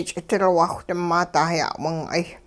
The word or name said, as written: ch'idilwaawh-ding maa dah-ya'wing'ay